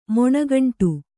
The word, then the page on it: ಮೊಣಗಂಟು: Kannada